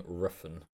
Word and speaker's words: Refn: Nicholas Winding Refn. – That's Refn.